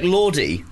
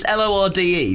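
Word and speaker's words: Lorde: It's L O R D E. The New Zealand guy is very passionate. – It's Lord, isn't it?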